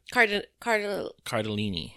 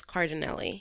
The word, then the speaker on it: Cardellini: Cardellini. – Cardellini. – I feel like I've been pronouncing it this whole time as Cardinelli.